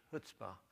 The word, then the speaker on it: chutzpah: There's a real common word among Jewish people that's called chutzpah, C-H-U-T-Z-P-A-H, chutzpah. The C is silent, chutzpah.